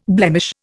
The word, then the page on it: blemish: Indian